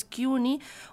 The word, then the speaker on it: CUNY: signan el Colegio Nacional y el Instituto de Estudios Mexicanos de CUNY, así lo pronuncian ellos, CUNY, un convenio que enriquecerá la investigación sobre nuestro país y nuestros compatriotas en Estados Unidos.